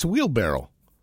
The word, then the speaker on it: wheelbarrow: You know, by the way, it's not barrel. It's barrel, borrow, barrel. What is that anyway? Whenever you say it, it's wheelbarrow.